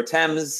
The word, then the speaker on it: Thames: The River Thames, see, I said it correctly, has frozen over.